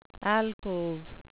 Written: alcove

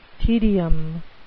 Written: tedium

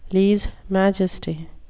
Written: lese majesty

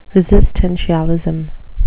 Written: resistentialism

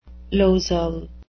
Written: losel

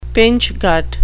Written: pinchgut

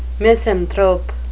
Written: misanthrope

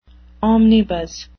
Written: omnibus

